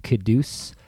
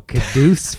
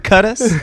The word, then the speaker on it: Quddus: Today, I'm here with Caduce Philippe. Did I say that right? – Yeah, you said it right. – It's a sexy name, bro. Caduce Philippe. – Well, when you say it right. – I mean, Jesus. – I've heard Cutus, Quetus. – Cutus. – A lot of unsexy versions.